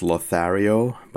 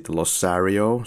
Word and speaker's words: Lothario: Then there is the line by Donna where she says Lothario, but actually pronounces it Losario, so that kind of a fail in itself.